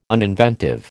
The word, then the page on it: uninventive: American English